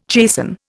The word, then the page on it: chasten: Indian